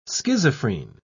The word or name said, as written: schizophrene